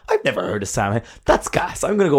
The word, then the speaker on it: Samhain: S-A-M. Oh my God. – And what bloody Supernatural did, Michael, was they took sound, S-A-M-H-A-I-N.